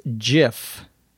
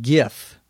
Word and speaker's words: GIF: It's pronounced by some people as GIF. Other people pronounce it GIF. I think GIF is the original pronunciation.